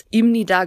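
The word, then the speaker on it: Imni da (입니다): Das heißt, wenn ihr jemals Imni da geschrieben seht, dann steht da zwar ein B, aber ihr könnt es als ein M aussprechen.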